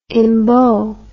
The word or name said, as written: embow